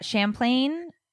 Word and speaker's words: Champlain: So this horrible condo collapse, it was at Champlain, is that how you say it? Because there's P-L-A-I-N, right? – Yeah.